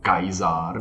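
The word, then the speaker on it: Caesar: Mm-hmm ale on se vyslovoval jinak to jméno. To se vyslovovalo něco jako Kaiser nebo tak nějak, že?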